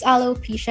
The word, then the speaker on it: alopecia: I think you're saying it correctly. It's alopecia. And I know a lot of people who suffer from this.